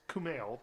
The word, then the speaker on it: Kumail: Kumail? Is it Kumail Nanjiani? – Yeah. – Ooh, I got it right. What? – It's Kumail, but close.